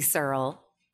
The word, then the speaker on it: Searle: Well, so my name is Kathy Searle, and it's spelled S-E-A-R-L-E. And I get Searle. When I was younger, it used to be Surly.